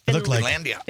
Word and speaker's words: Finlandia: Finlandia? Is that how you say it? – Finlandia. – Finlandia.